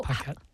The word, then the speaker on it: Paquette: Yeah, basically, it's a French name, but I'm American, so I pronounce it like an American. Paquette.